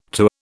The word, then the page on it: to: British English